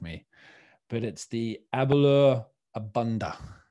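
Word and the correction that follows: A'bunadh: Abuna. – Abuna.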